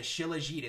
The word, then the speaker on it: Shilajit: Mountains are very powerful energy portals for Earth, and um, yeah, the Shilajit. It's spelled, I, I believe I'm gonna get this right, S H I L. A J I T Shilajit.